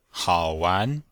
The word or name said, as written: hao3wan2